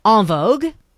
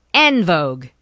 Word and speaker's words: En Vogue: So us here in Canada, we loving them call them En Vogue. In America, they call them En Vogue. Depending on how, you know, you use the E-N with the the French pronunciation of the vowel.